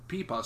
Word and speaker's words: pipa: She's an amazing player, and she pronounced it pipa. So I was like, okay, now I know how to pronounce it.